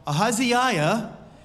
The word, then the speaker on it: Ahaziah: Now, Ahaziah, yes, that's five syllables in that short name. Ahaziah fell through the lattice in his upper chamber in Samaria and lay sick.